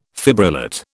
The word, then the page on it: fibrillate: British English